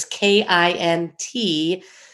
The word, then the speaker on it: kintsukuroi: There's the longer version, which is scarier, which is kintsukuroi.